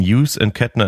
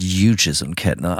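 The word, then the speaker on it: Hughes & Kettner: Hughes und Kettner. – Also das Hugh kommt dann tatsächlich aus dem Englischen.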